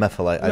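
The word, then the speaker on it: Emefiele: So, how do I say his name? Godwin, how do I say his MFLA. – Last name? MFLA. Yeah. I've messed that up so many times in my videos.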